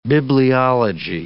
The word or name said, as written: bibliology